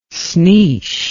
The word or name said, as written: sneesh